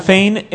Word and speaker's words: Thane: Que dice, no sé si sea spoiler la opinión, pero ¿qué les parece el Tane o Tane? ¿Cómo se dice? – Tane Hector. – Pues es T-H-A-N. – Sí, sí. Okay. Thane es básicamente... Thane.